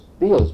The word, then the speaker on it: Beals: Beals, B-E-A-L-S, Beals.